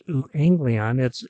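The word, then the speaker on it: euangelion (εὐαγγέλιον): The Greek word for gospel is euangelion, euangelion. Pronounced euangelion, it's translated into English as good news.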